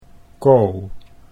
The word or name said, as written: Co